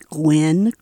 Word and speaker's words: Gwenn: I'm My name is Gwen Craig, and if it's spelled later, it's G-W-E-N-N, Craig, C-R-A-I-G.